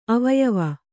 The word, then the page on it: ಅವಯವಿ: avayavi; Kannada